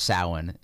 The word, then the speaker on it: Samhain: It's pronounced Samhain in the Celtic.